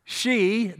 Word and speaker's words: Xi: Xi, that's the way they pronounce it, X-I, Xi Jinping,